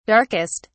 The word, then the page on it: darkest: British English